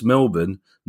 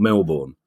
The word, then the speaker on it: Melbourne: And Marty Laidler, and I quote, says, "This is a question from a Sunderland supporter born in Middlesbrough, now living in Melbourne, pronounced Melbourne, not Melbourne."